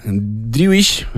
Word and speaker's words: Driouech: Se llama Driouech, o así parece pronunciarlo él,